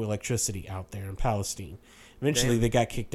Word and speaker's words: Palestine: They had to move to Palestine, Texas, which is spelled like Palestine, but we say Palestine here in mm-hmm.